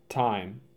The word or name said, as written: Time